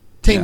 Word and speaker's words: Taymor: It's Julie Tam- Julie Taymor. Taymor. Yes.